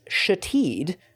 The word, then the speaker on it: Shithead: It was pronounced Shateed, but was in fact spelled Shithead.